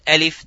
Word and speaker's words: alif: Alif does not have any mad at all. Just pronounce it the name of the letter, which is alif.